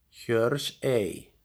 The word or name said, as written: Hjörsey